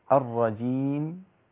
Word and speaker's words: الرجيم: لكن لما أعمل الرجيم، ليه الفتحة اللي أنا بحاول أفتحها؟ بعمل شبه حركة بين الياء المادية والحرف اللي أنا حسكن عليه اللي هو الميم. خليني بلا تكلف كما قال ابن الجزري. باللطف في النطق بلا تعسف، هتبقى الرجيم.